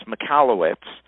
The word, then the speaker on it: Michalowicz: We'll have a link in the show notes, but it's Michalowicz.